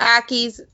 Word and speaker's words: Ackies: If you want a monitor, you could have that small as Ickies. – Ickies. – Am I saying it right? Ickies, Ickies, whatever. Ickies in a 75-gallon tank. – Ackies, maybe? – Yeah, Ackies are amazing.